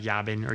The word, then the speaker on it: Jabin: Well, it'd be Hebrew, the J is pronounced with a Y. – Okay. – So it'd be like Yabin or Yabin. – Okay, Yabin. – But you can say Jabin. No one's going to get mad.